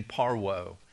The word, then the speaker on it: parvo: In short, James, the half-brother of Jesus, explains the tongues multum in parvo. Now, the way you pronounce that is like M-U-U-L-T-U-M, multum in par, and the V is a W in parvo.